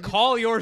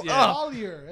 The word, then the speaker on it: Collier: Collier. That's how you say his name. – Sway. – Collier. – Collier, right? – No, but you said call your. – Yeah. – Like call your studio.